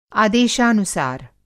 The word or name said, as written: आदेशानुसार